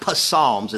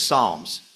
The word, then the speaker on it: Psalms: I was over a year and a half in the Lord before I realized you don't pronounce the P. It's not Psalms, it's Psalms.